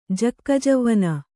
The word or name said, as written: ಜಕ್ಕಜವ್ವನ